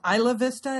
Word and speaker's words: Isla Vista: It's called Isla Vista, is how that's pronounced, yes.